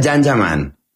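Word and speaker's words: Can Yaman: Se escribe Jan Yaman, se pronuncia Jan Yaman.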